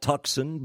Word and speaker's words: Tucson: Tucson, yes, it is spelled Tucson, but pronounced Tucson, where a great-grandfather of mine passed away.